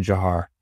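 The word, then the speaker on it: Dzhokhar: But, um, but it's, but it's actually Jahar is how he pronounced it.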